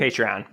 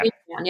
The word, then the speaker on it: Patreon: The one that I'm interested in right now, because I was really looking into I don't know how to say it, Patreon or Patreon? – Patreon, yeah. – Patreon, yes. So I was looking into that, and I actually created an account, but I didn't do anything with it yet.